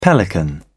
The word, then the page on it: pelican: British English